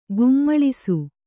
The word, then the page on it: ಕೋಮಲಿಸು: Kannada